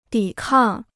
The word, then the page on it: 抵抗: dǐ kàng